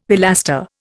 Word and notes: pilaster: Indian

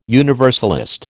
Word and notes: universalist: American English

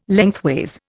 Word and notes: lengthways: Indian

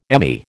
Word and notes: ME: American English

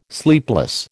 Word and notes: sleepless: American English

